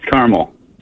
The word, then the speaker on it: Carmel: Now, how do the locals say it? Do you say Carmel or Carmel? I've heard both, but I think it's Carmel, if I'm not mistaken. – Yeah, it's Carmel.